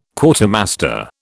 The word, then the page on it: Quartermaster: British English